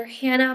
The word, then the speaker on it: Hanna: On Instagram, you can find me under Hanna Money Bliss.